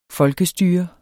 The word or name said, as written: FOLKESTYRET